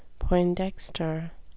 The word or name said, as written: poindexter